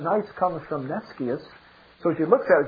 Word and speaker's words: nescius: The word nice comes from the Latin word nescius, or actually it should be pronounced nescius, which means ignorant.